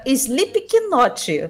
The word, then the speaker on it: Slipknot: o e-mail do Leonardo Cash, uh, Sleep Knot, como escreve como se fala, tá?